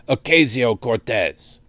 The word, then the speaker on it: Ocasio-Cortez: I've introduced this resolution in the Senate, along with in the House from Blumenauer and Alexandria Ocasio-Cortez. Does he not know how to pronounce her name? Ocasio-Cortez.